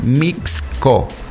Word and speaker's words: Mixco: Hay que pronunciar bien. Mixco. Porque si le quitan la X es otra cosa.